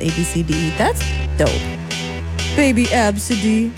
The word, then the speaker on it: Abcde: super shocked that a parent named their child A, B, C, D, E, but it's pronounced Absidy.